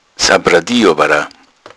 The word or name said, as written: sappradi